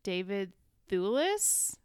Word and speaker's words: Thewlis: Bryant, Brandon Kyle Goodman, David Thewlis, is that how you pronounce his name? – Mm-hmm.